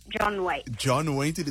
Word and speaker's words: John Waite: John Waite. I don't know if I'm pronouncing it right, but John Waite, that is spot on.